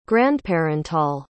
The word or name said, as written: grandparental